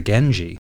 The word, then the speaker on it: Genji: The character for clan is generally read as she, but when it follows the N sound at the end of gen, it becomes G. So the Minamoto clan can also be called the Genji.